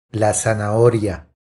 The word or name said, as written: La zanahoria